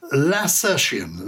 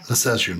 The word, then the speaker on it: lacertian: How do you pronounce it then? – Lacertian, yeah. – Lacertian.